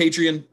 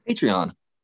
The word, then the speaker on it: Patreon: I don't like that. That doesn't sound good. – How do you say it? – Patreon. – Patreon. – Yeah. Patreon. Yeah. Patreon. Yeah. You're saying it like a weird fucking Scandinavian or something.